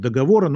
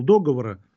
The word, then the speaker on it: договора: или правильно говорить, договора, но Брежнев говорил договора, а я думаю, я его очень уважаю, поэтому тоже всегда, как и Брежнев, говорю договора. Значит, была интервенция Германии, Австро-Венгрии, Турции, Румынии.